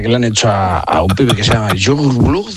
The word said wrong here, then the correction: Youngblood: Es que era muy difícil de pronunciar, pero luego, ¿cómo se pronuncia? – Youngblood.